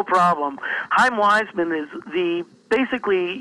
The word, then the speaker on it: Chaim Weizmann: The actual pronunciation is Chaim Weizmann, but Harry Truman, President Truman, called him Cham, and Weizmann never corrected him.